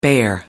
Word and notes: bear up: American English

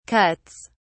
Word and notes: cuts: British English

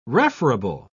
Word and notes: referable: British English

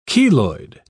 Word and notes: cheloid: British English

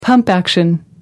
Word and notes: pump action: American English

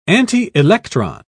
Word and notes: antielectron: British English

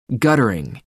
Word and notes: guttering: American English

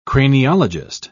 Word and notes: craniologist: British English

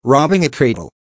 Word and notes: robbing a cradle: American English

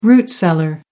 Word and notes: root cellar: American English

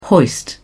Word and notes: hoist: British English

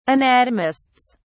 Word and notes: anatomists: British English

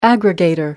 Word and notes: aggregator: American English

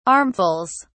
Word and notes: armfuls: British English

